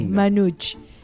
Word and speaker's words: manouche: Y fue, digamos, justamente uno de los creadores de este estilo llamado Gypsy Jazz, o Jazz Manouche. No sé si se pronuncia así, pero me gusta decirlo así. Manouche, exactamente.